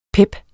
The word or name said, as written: pep